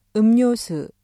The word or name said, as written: 음료수